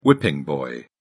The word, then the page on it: whipping boy: British English